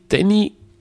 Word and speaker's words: Danny: Naja, Danny, das äh, schreibt man D-A-N-N-Y, spricht aber Englisch aus Danny.